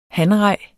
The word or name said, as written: hanrej